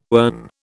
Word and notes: ern: British English

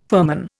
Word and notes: firman: Indian

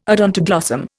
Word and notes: odontoglossum: Indian

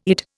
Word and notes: Eid: Indian

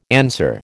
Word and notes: answer: American English

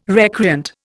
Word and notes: recreant: Indian

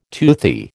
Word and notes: toothy: American English

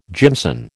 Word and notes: jimson: American English

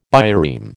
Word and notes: bireme: American English

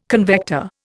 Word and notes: convector: Indian

